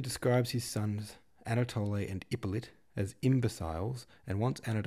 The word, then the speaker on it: Ippolit: That's pronounced Ippolit, and sometimes in some versions spelt I-P-P-O-L-I-T. So if you're wondering if they're the same person, Hippolyte and Ippolit, yes, they are.